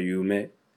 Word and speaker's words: 夢: 欢迎收听日语日语，我们的口号是日语里的梦念作 Yume，把英语里的字母 U 发音压低，接上一个介于 May 和 May 之间的 May，勉强可以用你妹来帮助记忆，你的梦就是你妹 U May Yume，